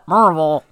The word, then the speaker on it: Maryville: And so the city that I was, it's called Maryville. – No. – But people there call it Maryville. – It is spelled Maryville. – But it is spelled Maryville. So when Steve keeps hearing, like, from my uncle, like, oh, yeah, we're in Maryville, he is looking for a city spelled M-U-R-V-I-L-L-E.